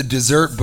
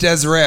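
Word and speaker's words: Deseret: I went to the dessert books. Have you been there? Dessert book. And somebody goes, it's pronounced Desiree.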